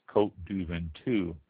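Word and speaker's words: Côtes du Ventoux: Next one, the Côte du Ventoux. That's spelled Côtesdu Ventoux. That's Côte du Ventoux.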